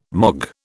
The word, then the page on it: mog: British English